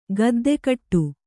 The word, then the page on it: ಗದ್ದಕಟ್ಟು: gaddakaṭṭu